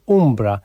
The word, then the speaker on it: Umbra: Chyba jednak tak powinno się wymawiać tytuł tego utworu. Chociaż z tytułem płyty już jest problem, bo artysta twierdzi, że może być też wymawiane jako Umbra, a nie Ambra.